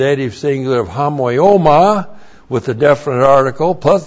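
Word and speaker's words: homoioma: Looks like that in the Greek, H-O-M-O-I, long O-M-A, hamoioma. Hamoioma means likeness, and then parabasis. Looks like that in the Greek, P-A-R-A-B-A-S-I-S, parabasis.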